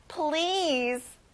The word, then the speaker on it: please: 정중하게 부탁할 때 덧붙이는 말, 제발, please. 자, 여러분, 긴 단어고요. 끝에 z 발음을 해보세요.